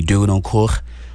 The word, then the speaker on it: Durancourt: If we're going to say my name the proper way, you'll have to say Jean-Bradley Durancourt. That's a French pronunciation, but yeah, you know, I'm pretty easy, Kevin.